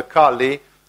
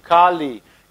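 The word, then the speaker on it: Kālī: Because in Sanskrit language, Kali is written with a long A and a long I. So it's Kali, and the word from Kali Yuga is written with a short A and I. And therefore, it's simply a different word.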